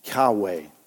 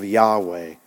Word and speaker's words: Yahweh: They inserted them into this tetragrammaton, and then it was rendered what we know as YAHWEH, Yahweh, Yahweh. And again, no one truly knows the exact pronunciation, but many believe that's exactly how it might have been pronounced back then, Yahweh.